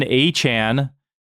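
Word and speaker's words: Achane: And by the way, we've been saying his name wrong this entire time. I was watching Red Zone A-chan. – This week. Yep. And they kind of corrected us all.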